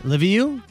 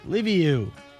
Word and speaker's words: Liviu: L-I-V-I-U. – LeVieux. Yeah. – LeVieux.